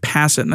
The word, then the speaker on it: Passan: However, the MLB made an economic proposal during the session, and according to The Athletic's Evan Drellich and ESPN's Jeff Passan, Jeff Passan, that's how you say his name.